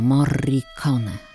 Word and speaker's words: Morricone: Tak wymawiał swoje nazwisko. Morricone. Przez dwa R. Bardzo wyraźne R.